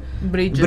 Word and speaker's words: bridge: Ci parla anche dei Bids, giusto? – Sì? – The Bids, the altro sport. – Bridge. – Bridge. – Un po' difficile a pronunciare. – Ha dimenticato consonante qua e là.